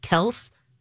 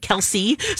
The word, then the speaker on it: Kelce: Now, of course, there's also been some, like, he revealed, and with his brother on their podcast, that actually their last name is pronounced Kels. But everyone said Kelce, so they just go with it.